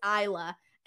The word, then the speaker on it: Isla: And then out of nowhere, well, it was like when she first came in, I'm like, oh yeah, Isla Dawn. And then everybody was like, no, bro, it's Isla.